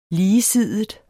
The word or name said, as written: ligesidet